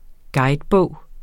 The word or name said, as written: guidebog